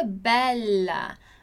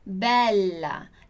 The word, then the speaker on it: bella: Então, eu digo bella em português e tudo bem, em italiano é bella, bella. La vita è bella, la vita è bella. Dillo chiaro? Bella, bella. A gente precisa demorar, alongar essa pronúncia do LA, ok?